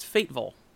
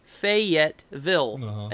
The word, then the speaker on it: Fayetteville: And I mentioned Fayetteville one time, and he laughed at me because he says it's Fateville. – Uh-huh. – But it's clearly Fayetteville, and he says it's Fateville.